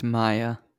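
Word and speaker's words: Maia: It's pronounced Maya.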